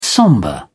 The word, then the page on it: sombre: British English